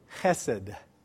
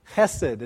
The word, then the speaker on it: chesed: Chesed. Chesed is how you say this word.